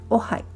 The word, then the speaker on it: Ojai: オハイ OJAI と書いてオハイというふうに発音します